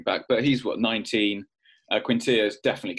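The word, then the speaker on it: Quintillà: And it feels like we've got best of both worlds, actually, because Quintilla feels like he's the more defensive-minded. You're smiling at me, Mike, as if I pronounced that. – No, it's perfect. It was absolutely perfect.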